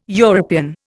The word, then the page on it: European: Indian